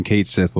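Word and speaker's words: Cait Sith: I'm looking at some screenshots, and one, Kate Sith looks amazing. – Yes. – Or Kaichi, if you want to say the name right or whatever, because it's Gaelic. It's like Kaichi or something like that.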